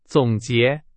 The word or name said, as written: 总结